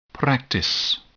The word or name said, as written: practice